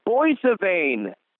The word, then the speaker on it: Boissevain: It's Boissevain. – That's the correct pronunciation. Congratulations.